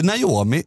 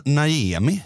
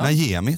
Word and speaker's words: Najemi: Inte Naomi, utan Najemi. – Okej. – Okay. Ja. Najemi, tror jag uttalar rätt.